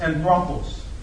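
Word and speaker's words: anthropos (ἄνθρωπος): And friends, that word man in the Greek is pronounced anthropos.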